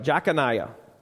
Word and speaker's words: Jeconiah: All right, and it's pronounced a couple different ways, but that's the basics of it. Jeconiah.